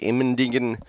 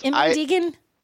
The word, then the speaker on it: Immendingen: I went to the Immendingen test facility. And is that really how you say that, or did you mutilate that? – Nope, that's how they say it.